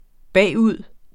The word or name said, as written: bagud